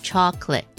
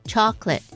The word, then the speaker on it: chocolate: 好，巧克力就是一个翻译的词、所以它的英文就是 chocolate。 Chocolate is spelled C-H-O-C-O-L-A-T-E。那请注意哦，它只有两个音节而已、你不要念成 chocolate、不是、 chocolate。